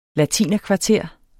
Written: latinerkvarter

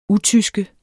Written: UTYSKERNES

